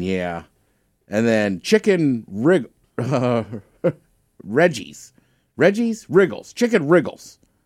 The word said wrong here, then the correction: Riggies: Oh, Riggies. Riggies? – Yeah, Riggies.